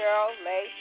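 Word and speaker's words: Cheryl Leigh: The street is spelled C-H-E-R-Y-L. Second word is L-E-I-G-H, Drive. That's 1698 Cheryl Lay Drive in the city of Riverdale, Georgia.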